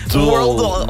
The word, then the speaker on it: Worldle: Worldle, comme le world, comme le monde.